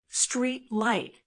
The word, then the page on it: street light: American English